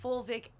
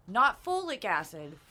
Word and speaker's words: fulvic: That it was fulvic acid and that there's not folic acid, fulvic. – What is that? – Which is spelled F-U-L-V-I-C.